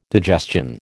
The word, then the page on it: digestion: American English